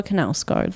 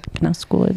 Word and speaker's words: Knausgård: Karl Ove Knausgård. – Karl Ove Knausgård. – Knausgård.